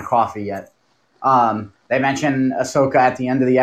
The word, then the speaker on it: Ahsoka: Uh, they finally mentioned, uh, dude, I'm going to butcher the name Asuka. Ahsoka. Ahsoka. No. God. What? What's what's the name? I can't talk today. You know, we were talking earlier, and I was saying Ahsoka. That's it.